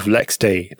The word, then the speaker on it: LXD: Ubuntu Maker Canonical pulls in control of LexD. That's how you say that, isn't it? LXD it's spelled. – Yeah, it is. Yeah.